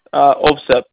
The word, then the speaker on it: Ovsep: G-A-R. – Okay, G-A-R. And last name? – OVSEP, O-V-S-E-P. – O-V-S-E-P.